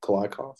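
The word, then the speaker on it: Kliavkoff: I know George Kolakoff, did I say that right? Kolakoff? – Close enough. Kolakoff.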